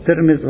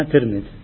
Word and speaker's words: ترمذ: تلفظ ترمز وتلفظ ترمز ولكن المشهورة كما ينسب إلى أهلها في كتب التاريخ الإسلامي أنها ترمز